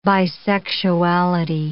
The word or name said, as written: bisexuality